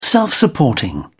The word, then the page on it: self-supporting: British English